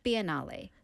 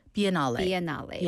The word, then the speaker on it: Biennale: How do you pronounce it? – Biennale. – Biennale.